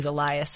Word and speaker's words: Elias: You said it was Elias, so I'm saying it wrong.